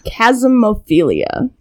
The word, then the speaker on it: chasmophilia: Chasmophilia. – Chasmophilia. – Chasmophilia. – Chasmophilia. – And it's spelled C-H-A-S-M-O-P-H-I-L-I-A.